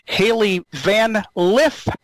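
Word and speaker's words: Hailey Van Lith: Haley Van Lith is the way you pronounce it officially.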